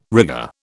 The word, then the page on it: rigger: British English